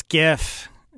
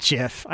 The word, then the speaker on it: GIF: It's GIF. It is not JIF. I know the guy that invented it says JIF.